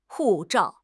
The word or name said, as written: hu4 zhao4